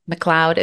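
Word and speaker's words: Macleod: McLeod. – Is that how you pronounce that? – Okay. Yes. – It's Scottish, I guess.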